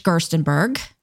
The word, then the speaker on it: Gerstenberg: So first, I want to introduce George Gerstenberg.